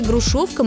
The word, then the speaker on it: грушовка: Есть такой старинный русский сорт народной селекции – грушовка московская. Ударение на втором слоге. Пишется через «о».